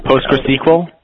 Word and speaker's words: PostgreSQL: I just say Postgres. PostgreSQL is a little too much for me. So Postgres, PostgreSQL. – Yeah, well, it could be. But the thing, you know what? I don't like pronouncing it SQL because SQL is like a Microsoft thing.